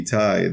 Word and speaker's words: Itai: But then, I asked my parents, did you know that when you were calling me Itai, that it rhymes with Yanai?